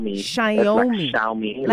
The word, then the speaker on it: Xiaomi: Xiaomi. – Like Xiaomi. – Like Naomi with a shy.